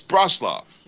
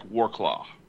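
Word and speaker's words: Wrocław: It's Broslov. – Yeah, Broslov. Where is that? Let me see. – It's spelled weird. It's W-R-O. – Yeah. – C-L-A-W. It looks like Warclaw.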